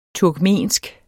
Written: TURKMENSK

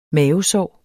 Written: mavesår

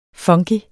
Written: funky

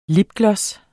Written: lipgloss

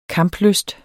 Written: kamplysten

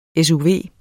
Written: SUV

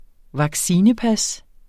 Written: vaccinepas

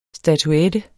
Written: statuette

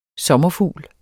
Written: SOMMERFUGLEN

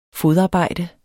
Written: fodarbejde

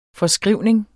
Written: forskrivning

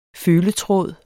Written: føletråd

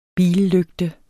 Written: billygte

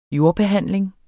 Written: jordbehandling

